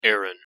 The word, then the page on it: Aaron: English